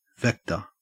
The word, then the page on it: vector: Australian English